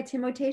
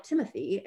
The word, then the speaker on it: Timothée: I love saying Timothée when I realize that's how you pronounce his name. People are like Timothy.